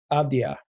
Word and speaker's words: Avdija: Going back to, I'm going to say his name wrong, Avdija.